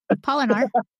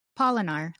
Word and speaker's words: Polinar: Polinar. – How do you say it? Polinar? – Polinar, like pollinating.